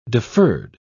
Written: deferred

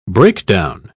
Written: breakdown

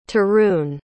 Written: tarun

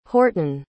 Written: horton

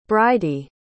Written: bridey

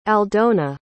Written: aldona

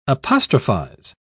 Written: apostrophize

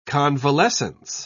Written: convalescence

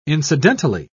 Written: incidentally

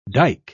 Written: dike

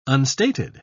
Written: unstated

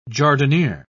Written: jardiniere

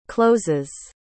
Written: closes

